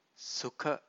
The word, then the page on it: sukha: Pali